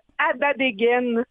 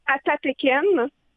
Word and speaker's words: Atateken: Donc, le fait qu'on prononce Adadegen ou Atateken, ça ne changera pas le sens.